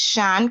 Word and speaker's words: Sian: Uh, so, those of you who don't know me, my name is Sian Cuffy Young. It's spelled with an I and pronounced with an H.